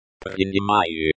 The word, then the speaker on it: Принимаю: Pronunciation. Принимаю. Принимаю. The stress falls on the second syllable.